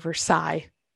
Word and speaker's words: Versailles: And there he also makes the joke of how he went the rooster rate for going to Versailles, Indiana, or Versailles, if you want to pronounce it correctly.